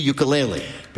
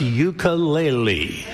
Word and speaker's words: ukulele: the ukulele. – Peter's first question to Taimane: how the hell do you pronounce ukulele? – Ukulele. – Ukulele. – You got it.